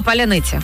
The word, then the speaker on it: паляниця: От, наприклад, наша славнозвісна, найпопулярніше наше слово – паляниця. – Паляниця, паляниця, да. Вони вже знають. – 140 мільйонів людей не можуть вимовити це слово, але перекладачі, сподіваюсь, можуть.